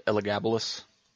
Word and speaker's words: Elagabalus: How do you say his name? – I think my professor said Elagabalus.